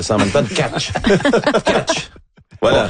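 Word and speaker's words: Tkáč: Et puis là, ben, il faut pas le prononcer Anton Tack. On avait tout fond en 76. – C'est ça. Selon ce que Juraj Benko, là, un auditeur d'origine slovaque, m'a dit, c'est Anton Catch.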